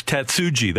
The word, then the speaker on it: Tatsuji: So I want to, it's Tatsuji, that part is the J we pronounce.